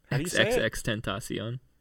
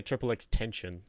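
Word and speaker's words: XXXTentacion: XXX Tentacion. – Tentacion, that's how you say it? – Mm-hmm. – I always want to say Triple X Tension. – No. He's not an extension cord.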